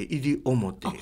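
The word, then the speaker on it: 西表: 日がいる、だから西の表と書いて入表。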